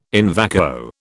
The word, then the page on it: in vacuo: British English